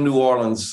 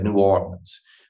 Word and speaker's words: New Orleans: Most people from New Orleans say it like you said, New Orleans. But there are some old school people in New Orleans who even say New Orleans.